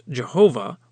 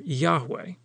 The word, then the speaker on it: Yahweh: The word hallelujah is the Hebrew phrase that means praise to Yah, with Yah being the short version of God's name, which may be pronounced Jehovah or Yahweh.